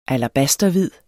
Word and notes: alabasterhvid: [ alaˈbasdʌ- ]

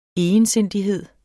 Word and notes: egensindighed: [ ejənˈsenˀdiˌheðˀ ]; [ ˈejənˌsenˀdiˌheðˀ ]

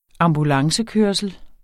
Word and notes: ambulancekørsel: -ˌkøɐ̯səl

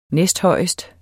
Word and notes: næsthøjest: ˈnεsdˈhʌjˀəsd; ˈnεsdˌhʌjˀəsd

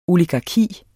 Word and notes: oligarki: [ oligɑˈkiˀ ]

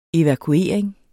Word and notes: evakuering: [ evakuˈeˀɐ̯eŋ ]; [ evɑ- ]

